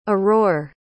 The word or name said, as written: aurore